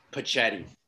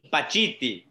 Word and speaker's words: Pacitti: I, I think it's Italian and it's Pachetti. – Close. Pacitti. – Pacitti.